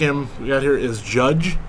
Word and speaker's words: Jydge: It's not Jidge? – No. Contrary to a lot of other podcasts and personalities' opinions, no, it is not Jidge. It's spelled J-Y-D-G-E. So it looks like it's Jidge, but if you actually spend, you know, 5-10 seconds in the game, you will come to find out.